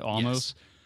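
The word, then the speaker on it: Omos: Is that how you say it? Almost? – Yes.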